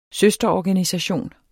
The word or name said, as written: søsterorganisation